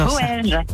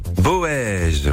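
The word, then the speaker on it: Boëge: Comment ça se prononce votre ville? – Bonjour famille, c'est Boège. – Boège, voilà, Boège.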